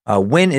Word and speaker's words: Nguyen: And I know I'm not saying Nguyen completely perfectly. It's spelled N-G-U-Y-E-N. But to me and other English speakers, a lot of them, it sounds real close to just Nguyen, which is, you know, pretty solid name, better than Luz.